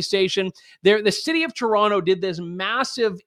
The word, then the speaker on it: Toronto: I did the Toronto. You're supposed to say Toronto. I never pronounce the second T.